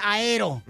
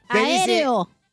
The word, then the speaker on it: aéreo: Va, tito y te desarmo presenciaron un accidente aero. No, no se dice aero. ¿No? aéreo. – Aéreo.